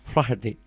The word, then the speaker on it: Flaherty: Flaherty. – Flaherty, así se pronuncia.